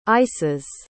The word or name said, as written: ices